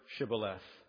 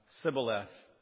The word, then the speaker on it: Shibboleth: Say the word Shibboleth. Sibboleth." Oh, caught you red-handed. Apparently, the Ephraimites could not say, they could not pronounce Shibboleth. It was Sibboleth.